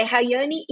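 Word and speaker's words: Rayani: E no meu Instagram pessoal, que é Rayane Imediato, tudo junto.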